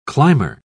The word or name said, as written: climber